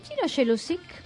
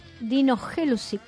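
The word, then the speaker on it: Dino Jelusić: Dino Jelusic, Dino Jelusic, así exactamente se pronuncia.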